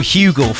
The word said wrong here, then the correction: Hugel: Found out today it's Ugel. – Is it? – It's pronounced Ugel, yeah. And that's from Ultranate's official Instagram. – Oh, I call him Hugel as well. – DJ Ugel. – Oh. – Drop the H, Ugel.